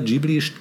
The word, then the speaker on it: Ghibli: Ja, der, der Name äh, Ghibli geht auf eine italienische Bezeichnung zurück, die wiederum arabischen Ursprungs ist und äh, wird im Japanischen aber statt mit einem italienischen hartem G mit einem stimmhaften J-Laut mhm. ähm, ausgesprochen.